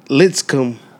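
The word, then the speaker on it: Lipscomb: We got North Carolina, Dorian's team playing Litz Cup. Did I say that right? – Litzcomb.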